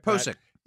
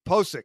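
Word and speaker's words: Pocic: Pošick. – Pošick.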